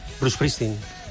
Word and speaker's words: Bruce Springsteen: Sí, bueno, nosotros es que la S... – Claro. – Pronunciamos la E delante, ¿no? – Claro, nos decimos Bruce Springsteen. – Springsteen. – Claro. – Sin sí. – La S del medio también, ¿eh? – También.